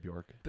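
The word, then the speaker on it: Björk: kind of like Bjork or Bjark. I don't know how you pronounce her name. – I've always just said Bjork.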